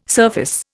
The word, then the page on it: surface: Indian